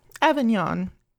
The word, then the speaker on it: Avignon: But she also has Colonel Brandon's presumably English sister living in Avignon. I probably butchered that. – Avignon. – Sorry. It's Avignon. French.